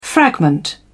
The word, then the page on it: fragment: British English